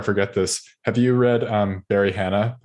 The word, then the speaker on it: Hannah: Hannah, Barry Hannah. – Oh, Barry Hannah. – Okay. Yeah. Like Hannah. – Like the lady's name.